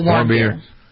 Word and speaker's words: Warmbier: Otto Warmbier.